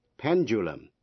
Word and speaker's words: pendulum: Procedure, P-R-O-C-E-D-U-R-E, procedure là thủ tục. Graduate, graduate, sinh viên tốt nghiệp. G-R-A-D-U-A-T-E, graduate. Pendulum, P-E-N-D-U-L-U-M, quả lắc đồng hồ. Và schedule, schedule là thời biểu. Vậy bốn chữ, procedure, graduate, pendulum, schedule, trong đó âm D phát âm là J.